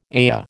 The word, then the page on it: ayah: American English